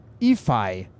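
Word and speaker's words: Iffe: Så nu skal vi altså åbenbart ikke kalde ham Ife Lundberg, men han skal hedde Ify Lundberg. Det siger de, man skal sige.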